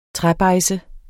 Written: træbejdse

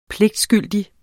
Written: pligtskyldig

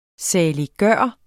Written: saliggørende

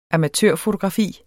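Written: AMATØRFOTOGRAFI

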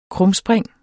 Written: krumspring

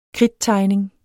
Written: kridttegning

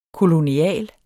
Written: kolonial

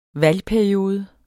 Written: valgperiode

